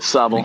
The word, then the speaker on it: Sobble: Sobble.